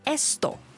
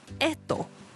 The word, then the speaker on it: esto: 嗱，我俾个例子大家啦，如果咧我想指呢、这个呢样，咁西班牙文正确或者系清楚嘅读音咧会系 esto。系啦，咁但系咧，古巴人咧就会省略咗个 S 音咧，读成 edo。